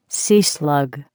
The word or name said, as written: sea slug